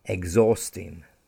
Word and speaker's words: exhausting: For example, exhausting. I was exhausted after the long journey. The football match was exhausting. Here, the H is silent. It's spelled E-X-H-A-U-S-T-I-N-G, but we stress without exhausting.